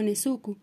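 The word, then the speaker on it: nezuku (根付く): Una planta en maceta se enraiza y la palabra japonesa para echar raíces se pronuncia como nezuku. El principal problema aquí es que es homónimo de nezuku, que significa quedarse en la cama.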